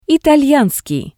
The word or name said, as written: italyanskii